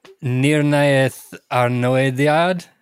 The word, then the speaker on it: Nirnaeth Arnoediad: Oh gosh, Nirnaeth Arnoediad. – Hey, that was actually pretty damn good.